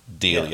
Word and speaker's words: Delia: Delia. – Okay. – You're really good at putting extra syllables in there.